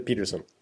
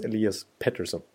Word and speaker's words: Elias Pettersson: då liksom då slog TSN fast nu att nu ska vi säga Pettersson, inte Peterson, så nu är det utrett. De blev nerringda och nermailade och sådär att han faktiskt ska uttalas Elias Pettersson, så att nu vet vi det, nu kommer han inte uttalas fel hädan efter i karensisk tv i tanken.